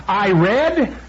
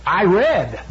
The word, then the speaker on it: read: Por ejemplo, la palabra leer en inglés es read, ¿sí? Pero si tú dices en el tiempo pasado, I read, se escribe igualito, I read the book. Es la mismísima palabra, se pronuncia diferente.